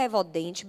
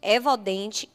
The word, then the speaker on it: Evodent: Evodente, o T é mudo, pelo amor de Deus. E-V-O-D-E-N-T. Evodente vai aparecer logo, que é referência aqui em nossa região.